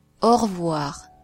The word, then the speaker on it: au revoir: The letter A plus U is pronounced O, and the letter E is usually not pronounced, so you say au revoir. The letter O plus I is pronounced wa.